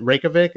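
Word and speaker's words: Reykjavík: You know, after 2016 Arcanum AP, which garnered the band attention throughout the local music scene, and is it Reykjavik?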